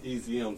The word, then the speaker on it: EZIUM: Okay, so my, it's pronounced EZM, so I like the letters.